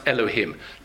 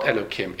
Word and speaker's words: Elohim: And in those cases, it should be pronounced Elohim, not Elokim. It's wrong to call it Elokim, because Elokim is respect for when it refers to Hashem.